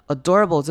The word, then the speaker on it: adorable: Adorable 这个字前面开头的 a 要念成轻音，不要念成 a 或长母音 a 或者短母音 a，所以是 adorable， adorable。然后这个字也要注意它的 r 的声音一定要发出来，很容易因为 or 然后后面接的声音，所以就会忘记了 adorable， adorable， adorable in an effort to。